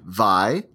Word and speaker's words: Vai: Hawaiian pronunciation, Vai, like Count Dracula saying Y. Oh, so I even did it wrong. Vai, like Count Dracula saying Y, followed by a glottal stop, then Ala, like the French Ala, blended at the end with an unsure eh. Though a frequent pronunciation is Y-A-L-I.